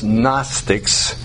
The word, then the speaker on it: Gnostics: It's G-N-O-S-T-I-C-S, and the G is silent. It's pronounced Gnostics. Gnostics.